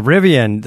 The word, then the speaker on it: Rivian: There's also the Rivian. Am I saying that right? – Yeah, Rivian.